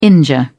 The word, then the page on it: injure: British English